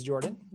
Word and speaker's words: Jordan: Uh, my name is Jordan with a silent PH in the middle.